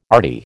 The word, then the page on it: arty: American English